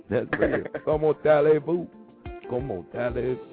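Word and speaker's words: Comment allez-vous: So it's not silent, but it's not said as it's read. So you wouldn't say comment. Allez-vous is como tallez-vous.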